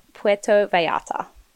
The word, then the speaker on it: Puerto Vallarta: I've written down the pronunciation so I don't fuck it up. Puerto Vallarta. – Oh, very nicely done.